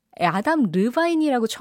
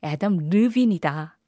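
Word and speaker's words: Adam Levine: 또 아담 르바인이라고 처음 막 불렀을 거예요. 아담 르빈이다.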